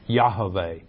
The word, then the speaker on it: Yahweh: His name is YHVH, which is pronounced Yahweh.